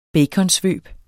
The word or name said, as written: baconsvøb